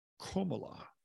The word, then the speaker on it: Kamala: Earlier in the event, Biden also fumbled by seeming to forget Harris's first name, calling her Kamala rather than the pronunciation of Komala.